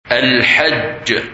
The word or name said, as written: Hajj